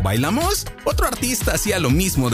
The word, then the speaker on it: Bailamos: Curiosamente, el título se canta con el acento cambiado, Bailamos, en vez de Bailamos. El compositor británico Paul Barry no hablaba español y aprendió mal la pronunciación.